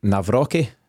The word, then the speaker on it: Nawrocki: Nawrocki.